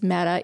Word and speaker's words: Metta: So I think it's kind of funny that my name is Metta, even though it's spelled with two T's, because I do love metaphor and I speak in metaphor a lot.